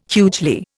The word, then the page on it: hugely: Indian